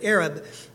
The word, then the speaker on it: Ereb: We see that Exodus 12:38, or not kreb, but Arab, E-R-E-B is how it's spelled.